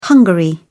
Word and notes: hungary: British English

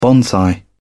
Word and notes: bonsai: British English

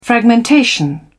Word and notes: fragmentation: British English

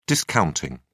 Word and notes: discounting: British English

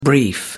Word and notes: brief: American English

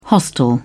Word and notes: hostel: British English

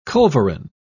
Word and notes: culverin: British English

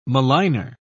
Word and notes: maligner: British English

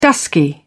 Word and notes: dusky: British English

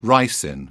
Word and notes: ricin: British English

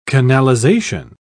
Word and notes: canalization: British English

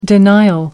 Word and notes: denial: American English

